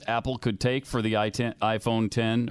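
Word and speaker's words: iPhone X: It's pronounced iPhone 10, but it's it's it got is. the letter X.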